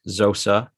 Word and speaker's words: Nzosa: With the 56th pick taken 54th overall, however we're choosing to phrase that these days, the Wizards took Jannik Zosa. It's spelled N-Z-O-S-A, but am I saying his last name right? – Yeah, from what I know, that's the correct pronunciation.